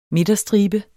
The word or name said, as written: MIDTERSTRIBE